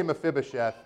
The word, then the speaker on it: Mephibosheth: But I say Mephibosheth, and so you're going to say Mephibosheth too.